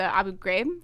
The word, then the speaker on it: Abu Ghraib: And so they in this book, they actually talk about the Abu Ghraib.